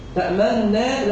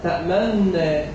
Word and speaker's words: تأمنا: قالوا يا أبانا ما لك لا تأمنا على يوسف، فيها إشمام إن أنا أضم الشفتين عند النطق بالنون. طبعا عندي النور دي مضمومة النور، لكن دي تأمنا، لا تأمنا تأمنا على يوسف وإنا له لناصحون.